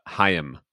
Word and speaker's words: Chaim: And secondly, I looked it up. Chaim. – It is Chaim? – Two syllables.